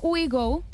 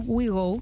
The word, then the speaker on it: OUIGO: Por ejemplo, WeGo, que se escribe O-U-I-G-O, WeGo, este, estás fantástica para tomar trenes de alta velocidad desde Madrid hasta Barcelona por tan solo 15 euros.